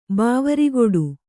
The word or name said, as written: ಬಾವರಿಗೊಡು